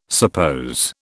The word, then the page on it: suppose: British English